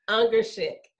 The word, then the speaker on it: Ungashick: Everybody trips up over it. It's Ungashick. – Ungashick.